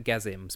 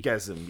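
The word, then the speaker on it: Gasim: Kasim. – It was I think it was Gazim, spelled with a G. – Gazim.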